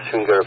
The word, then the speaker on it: Schwinger: Yeah, that's the Schwinger effect. Schwinger, do I say it properly? S-C-H-W-I-N-G-E-R?